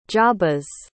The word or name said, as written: jabez